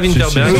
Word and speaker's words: Vinterberg: Je sais pas si je prononce bien. – Thomas Vinterberg.